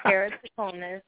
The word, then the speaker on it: keratoconus: Keratoconus. And they're probably confused because y'all. – Yeah, I'm sorry. – But it's pronounced keratoconus, or also known as KC for a shorter term.